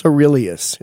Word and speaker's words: Coeruleus: So, MK, it's Locus Coeruleus. It's a soft C.